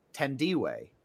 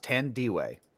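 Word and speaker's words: Thandiwe: I read into the trivia that it's pronounced Tandiwe. – Tandiwe. Okay. – It says pronounced Tandiwe.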